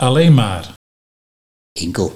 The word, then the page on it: Enkelt: Nieuw lekkerlands